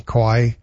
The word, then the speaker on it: kawaii: Kawaii? – Kawaii, K-A-W.